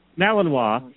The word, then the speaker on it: Malinois: Malinois.